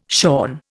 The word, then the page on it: shorn: Indian